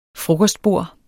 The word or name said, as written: frokostbord